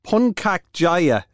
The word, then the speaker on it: Puncak Jaya: So over the course of Lent and every Saturday from this Saturday, Puncak Jaya, is that the way you pronounce it? – Yeah, that's it exactly. Yep.